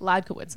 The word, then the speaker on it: Ladkowitz: And whenever someone like is like, wait, so I spell it out for them, and they're like, wait, how do you say that? I'm like Ladkowitz, and they're like, oh, that's easy.